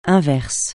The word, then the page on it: inverse: francais